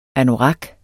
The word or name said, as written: anorak